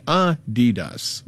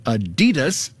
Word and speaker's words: Adidas: It's supposed to be Adidas, with a slight emphasis on the first syllable, not Adidas, stressing the second.